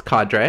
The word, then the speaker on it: cadre: Cadre? Cadre? How do you say that word? – No, it's cadre.